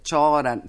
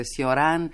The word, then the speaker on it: Cioran: che io lo pronuncio alla francese, si può dire Cioran, si può dire Sioran,